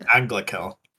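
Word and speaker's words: Anglachel: A great sword named Anglakel. Is that how you say it? – Anglakel. – Anglakel. Yeah, sorry. Anglakel.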